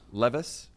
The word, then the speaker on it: Levis: How do you pronounce his last name? – Le- Will Levis? – Levis.